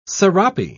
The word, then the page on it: serape: British English